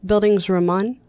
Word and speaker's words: Bildungsroman: How do you say it again? – Bildungsroman. – That's always going to be Bildungsroman. – So yeah, don't say dungs.